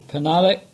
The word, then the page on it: Penhallick: English